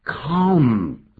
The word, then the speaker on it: calm: 这个 calm 这个发音呢、你看有个 L，后面念成 calm。 Down，其实也可以啊，有各种发音，L 不念也有。